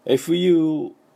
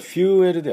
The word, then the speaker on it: fuel: で今日のメインテーマである燃料、これですね、 FUEL なんです。これどうしてもですね、これ日本人が普通に読みますとフューエル、 FUEL ですからフューエルって読んでしまうんですけれども、これフューエルではなくてですね、 EUE はですね、ウーっとこれで発音するんです。